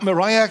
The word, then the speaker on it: Mariah: Und vorletztes Jahr hat Mariah Carey, die heißt nicht Mary Carey, sondern die hat sich den Namen anders schreiben lassen, Maria mit H hinten und dann spricht man es auch gleich anders aus.